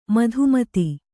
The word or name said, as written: ಮಧುಮತ್ತೆ